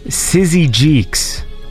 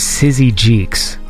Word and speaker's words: Syzygyx: So this is a track by, I believe it's pronounced Sizzy Jeeks, like S-Y-Z-Y-G-Y-X. And I think they said it was Sizzy Jeeks, like that.